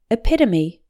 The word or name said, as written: epitome